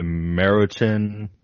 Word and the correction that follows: Maruchan: or what is it, Meriton? whatever. – Maruchan. However you want to pronounce those.